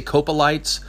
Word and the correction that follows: Coprolites: but it's really um, Coprolites.